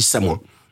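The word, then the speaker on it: Samoëns: Et comme c'est du patois et comme bah, le patois se prononce comme ça, on dit Samoin.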